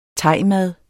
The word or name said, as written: thaimad